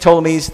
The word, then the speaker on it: Ptolemies: And then there's this continuing conflict between the Ptolemies, that P is silent from my understanding, and when you think Ptolemies, think Egypt, okay?